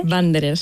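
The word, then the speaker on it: Wanderer: Nos hablas en tu capítulo del término, a ver si lo digo bien, ¿eh? Que uh-huh. Banderer. – Banderer, sí, efectivamente. Banderer. Muy bien.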